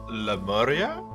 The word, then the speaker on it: Lemuria: Blavatsky calls the third race the Lemurians after the continent they occupied. – Lemuria? – Yes, that's exactly right.